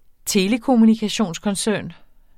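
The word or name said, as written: telekommunikationskoncern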